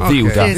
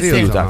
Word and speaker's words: Ceuta: Come se ci fosse il TH inglese, Ziuta. Ziuta. Con l'accento rigorosamente sulla prima sillaba, ragazzi. Non mi fate incazzare. – Ziuta. – No, Ziuta. Ziuta. – Non Piuta.